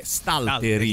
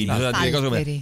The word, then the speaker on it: Stalteri: Stalteri, la pronuncia è Stalteri, Stalteri.